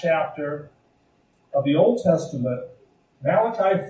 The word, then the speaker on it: Malachi: not as some have pronounced Malachi. He wasn't an Italian prophet, he was Jewish. But his name is Malachi,